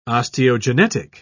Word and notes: osteogenetic: British English

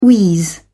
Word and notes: wheeze: British English

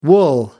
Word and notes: wool: American English

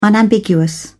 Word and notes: unambiguous: British English

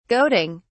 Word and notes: goading: British English

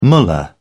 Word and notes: mullah: British English

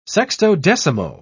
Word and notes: sextodecimo: British English

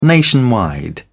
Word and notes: nationwide: British English

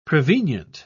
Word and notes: prevenient: British English

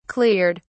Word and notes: cleared: British English

